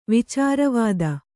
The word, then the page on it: ವಿಚಾರವಾದ: Kannada